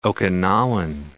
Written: Okinawan

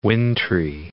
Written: wintry

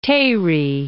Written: Tehri

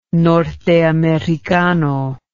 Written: norteamericano

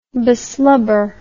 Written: beslubber

